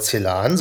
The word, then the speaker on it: Celan: Und zwar Anne Findeisen, die heute keine Stimme hat, hat ein Buch mitgebracht, und zwar ein Gedichtband von Paul Celan, so möchte er ausgesprochen werden.